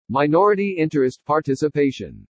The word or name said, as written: MINORITY INTEREST PARTICIPATION